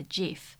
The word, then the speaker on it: GIF: Yeah, so logic would say that you would pronounce it GIF with hard G because it stands for graphic interchange format, but actually the creator of GIF himself, who coined the term in 1987, he actually calls it a GIF.